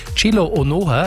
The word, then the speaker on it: Chilohem Onuoha: Ausgesprochen wird er aber, und da habe ich extra bei den Hartbergern nachgefragt. Eigentlich dankenswerterweise Chilo Onuoha, also das U ist sozusagen stumm.